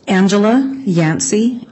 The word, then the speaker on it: Angela Yancey: Angela Yancey, A-N-G-E-L-A-Y-A-N-C-E-Y.